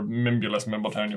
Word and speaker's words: Mimbulus Mimbletonia: Nimbulus Nimbletonia. Or Mimbulus Mimbletonia, whatever. – Yeah, Mimbulus Mimbletonia. – You did both spell it wrong, but it is Mimbulus Mimbletonia.